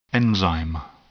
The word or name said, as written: enzyme